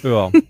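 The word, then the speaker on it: Oer: Ach, Ö, Ö, Ö. – nicht ja, Öhr. Danke. Öhr.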